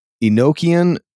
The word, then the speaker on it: Enochian: But I think it is pronounced Enochian, but then the vowel O is short. Enoch is how I've always heard it pronounced.